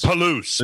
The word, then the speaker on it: Palouse: and Palos Strait State Palos? Palos? Palouse. – Palouse. Palouse Falls State Park. Palouse rhymes with caboose. Perfect. Palouse. Palouse the caboose.